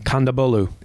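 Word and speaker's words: Kondabolu: Kandabolu.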